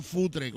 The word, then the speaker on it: Futre: Ahora, no ha dicho cutre, ha dicho futre con F.